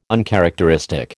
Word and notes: uncharacteristic: American English

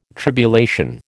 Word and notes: tribulation: American English

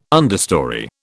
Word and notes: understorey: British English

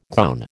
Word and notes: gown: American English

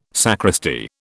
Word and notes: sacristy: British English

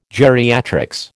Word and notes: geriatrics: American English

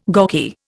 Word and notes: gawky: Indian

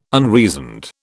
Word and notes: unreasoned: British English

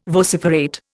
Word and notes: vociferate: Indian

